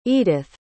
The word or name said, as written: eadith